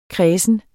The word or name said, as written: kredsen